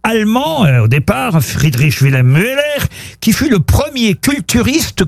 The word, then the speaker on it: Müller: Ou Müller. Ça s'écrit M-U-E-2-L-E-R.